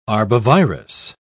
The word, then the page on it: arbovirus: British English